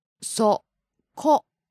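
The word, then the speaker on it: そこ: And here's an intonation point, always stressing, practice the pronunciation and the intonation when you learn these words.For koko, soko, and asoko, you'll notice that the pronunciation, there's a pattern.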